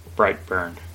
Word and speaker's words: Brightburn: I thought that was going up against Brightburn.